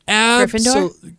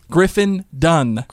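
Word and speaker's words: Griffin Dunne: Wait, seriously? That's how you would say his name? – His name is Griffin Dunn. – Gryffindor? – You're a wizard, Harry. – No, Griffin Dunn.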